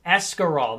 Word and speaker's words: escarole: Oh, here we go. It's an E. It's Escarol. That's how it's spelled. That's right. It's Escarol. That's how it's spelled. E-S-C-A-R-O-L-E and beans.